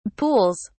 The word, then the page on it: pools: British English